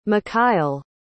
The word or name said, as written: macayle